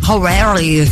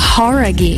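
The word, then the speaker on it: Jauregui: É Lauren Horaghi. Talvez você já tenha ouvido falar dela, ex-integrante do grupo Fifth Harmony. – Horaghi. – Não é? Horaghi. – Horaghi. – Horaghi. Então a gente tava falando errado, cara.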